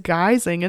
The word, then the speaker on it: guising: Trick-or-treating exists, known as guising in Scotland, pronounced like disguising,"